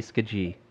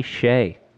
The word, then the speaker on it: Skjei: S-K-J-E-I. – Brady Skaji. Brady Skaji. – There's a lot of silent letters in this one. I'll give you a hint. – Brady Sky. – It is Brady Shea. – What in the hell? S-K-J-E-I? – Yes, Brady Shea.